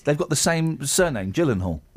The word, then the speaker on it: Gyllenhaal: Jake Gyllenhaal. – Yeah. – It's a soft G. Mm. It's like you don't pronounce the Moog synthesizer Moog. You pronounce it Kelly. – Mooge. – Moog. – Right. Jake Gyllenhaal.